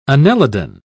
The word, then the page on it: annelidan: British English